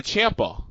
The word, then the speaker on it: Ciampa: So you said Ciampa. – Yeah, that's how you say it.